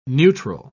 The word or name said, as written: neutral